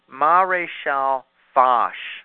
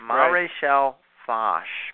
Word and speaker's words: Maréchal Foch: I won't pronounce it how I... said it back then, but it turns out that it's Maréchal Foch. – Foch. – Maréchal Foch is how you're supposed to say it.